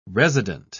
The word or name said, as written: resident